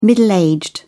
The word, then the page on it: middle-aged: British English